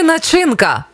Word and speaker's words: начинка: Начинка чи начинка? Як правильно? Наголос на перший склад. Начинка. Ось так правильно.